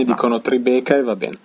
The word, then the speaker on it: Tribeca: No, una domanda, si legge Tribeca? – Sì, Tribeca. Qua dicono Tribeca.